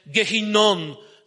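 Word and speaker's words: Gehinnom: Wiecie, pierwotnym miejscem jego działania było wzniesienie, które się w Izraelu nazywa Tofet, w dolinie Hinma, co po hebrajsku wymawia się Gehinnon, czyli dochodzimy do słowa, które znamy, Gehenna.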